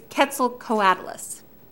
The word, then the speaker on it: Quetzalcoatlus: If you want to be able to know how to talk about it, it's Quetzalcoatlus.